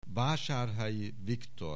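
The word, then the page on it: Vasarely Viktor: Hungarian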